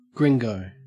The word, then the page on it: gringo: Australian English